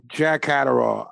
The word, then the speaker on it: Catterall: Jack Catterall.